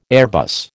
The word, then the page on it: Airbus: American English